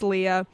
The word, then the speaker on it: Leah: My name is Leah.